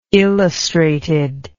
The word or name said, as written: illustrated